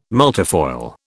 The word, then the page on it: multifoil: British English